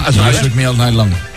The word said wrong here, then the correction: Shook Me All Night Long: Me cuesta el Shook Me All Night Long.